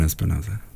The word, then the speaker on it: Espinoza: Efren Espinoza.